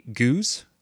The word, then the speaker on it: Gueuze: and that produces a Guse, which is spelled G-U-E-U-Z-E.